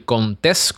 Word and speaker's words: Contés: Así que mi nombre es Miguel Contés con acento en la E y nos vemos la próxima.